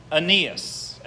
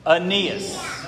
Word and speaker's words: Aeneas: Everyone say that with me. It's hard to say. Aeneas. Okay? Me and some others did a lot of work to figure out how to say this right, and we're pretty sure it is Aeneas.